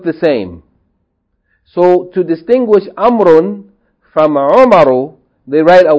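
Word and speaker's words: Amrun (عمرو): The proper noun, Amrun, is written with a waw, which is not pronounced. The waw is not pronounced. It's written, but it's not pronounced. Amrun, Amrun.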